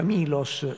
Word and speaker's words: Melos: Venere di Milo, è, perché il termine Melos, la, il mm, toponimo, eh, si pronuncia Milos nel, eh, e quindi si tratta dello stesso luogo.